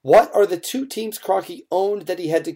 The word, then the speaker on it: Kroenke: I can never pronounce this guy's last name, so help me out because I'm sure you know. Rams owner Stan Kroenke? – Kroenke, yeah.